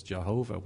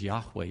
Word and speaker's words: Yahweh: So people mostly know it as Jehovah, but don't let that confuse you, that it's Yahweh is the right pronunciation of it, as far as we know at this point.